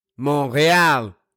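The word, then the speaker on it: Montréal: They were already here, so why not Canada, Toronto, Montreal? Yes, that's the right pronunciation, en français.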